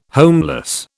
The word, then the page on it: homeless: British English